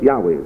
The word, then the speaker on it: Yahweh: And it is in fact from early Greek translations of the books of Genesis and Exodus that scholars now believe that it should be pronounced Yahweh.